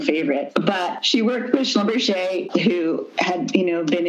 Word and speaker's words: Schlumberger: and even jewelry by Slumberger. I'm probably um, not saying that correctly. – Schlumberger.